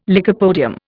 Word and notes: lycopodium: Indian

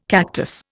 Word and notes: cactus: Indian

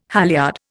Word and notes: halyard: Indian

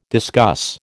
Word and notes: discuss: American English

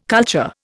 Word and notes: culture: Indian

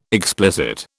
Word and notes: explicit: British English

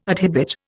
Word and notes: adhibit: Indian

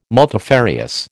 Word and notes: multifarious: American English

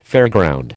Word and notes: fairground: American English

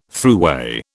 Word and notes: throughway: British English

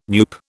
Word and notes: NUPE: British English

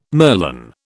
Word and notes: merlon: British English

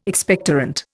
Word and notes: Expectorant: Indian

